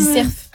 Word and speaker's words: cerf: J'ai dit cerf. – Ah oui, cerf. Ouais. Ah, et dans le sud-ouest, on prononce toutes les lettres. – Et dis le F.